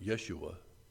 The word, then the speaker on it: Yeshua: In Hebrew, you would pronounce it Yeshua. In English, you would pronounce it Joshua.